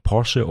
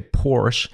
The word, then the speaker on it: Porsche: It's what Porsche, or as the Americans say, Porsche.